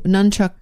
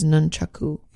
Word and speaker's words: nunchaku: So nunchuck is just our way of pronouncing the word incorrectly, which is nunchaku.